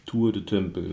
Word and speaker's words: Tour du Temple: wird in den Zellen des Tour du Temple, oder wie spricht man das? – Tour du Temple, ja.